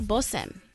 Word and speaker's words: bosem: It's kanai bosem. – Oh. – Yes. Kanai bosem. That's a component of the biblical anointing oil. – It does sound like bosom. – Okay, B-O-S-E-M is how it's spelled.